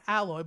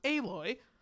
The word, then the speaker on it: Aloy: I almost call it Alloy, but no, it's pronounced Aloy.